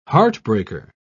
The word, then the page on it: heartbreaker: British English